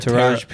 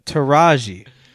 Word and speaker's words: Taraji: I forgot a whole syllable. – Tara? – Taraji.